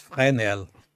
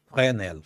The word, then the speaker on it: Fresnel: que tinham um nome que era se tu souberes pronunciar em francês, o teu francês deve ser melhor que o meu. É. pois eram as lentes de Fresnel. Fresnel. Fresnel.